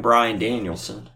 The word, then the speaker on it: Bryan Danielson: We kicked off Dynamite with a fucking 30-minute masterpiece between motherfucking Kenny Omega and motherfucking Bryan Danielson.